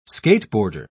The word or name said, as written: skateboarder